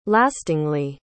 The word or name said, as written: lastingly